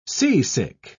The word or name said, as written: seasick